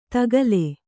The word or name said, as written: ತಕ್ಕಿಲು